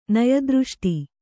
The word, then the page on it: ನಯದೃಷ್ಟಿ: naya dřṣṭi